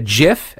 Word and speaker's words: GIF: If you have ever shared an image on social media, like a GIF, and that is the correct pronunciation of it, G-I-F, a GIF, which is like the little short clips of like 1 second long, you know, from a movie or something.